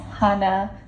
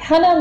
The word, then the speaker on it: Hannah: And the correct pronunciation is Hannah. So you were close. – Hannah with sort of a soft A. – Exactly, exactly. Hannah like honey.